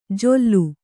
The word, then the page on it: ಜೊಲ್ಲು: Kannada